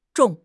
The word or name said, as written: zhong3